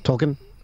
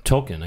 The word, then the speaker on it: Tolkien: How do you say that? T-O-L-K-I-E-N. Tolkien? – Tolkien, I guess. – I think Tolkien.